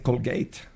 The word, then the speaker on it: Colgate: Entonces, bueno, no se dice Colgate, se dice Colgate.